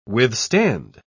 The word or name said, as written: withstand